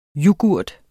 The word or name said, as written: Yoghurten